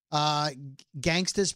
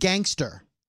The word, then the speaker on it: gangsta: He mispronounced it. – He said gangster. – Oh. – And so Alex Trebek said, that's correct. And then he went, hold on. The judges are saying, it's gangsta.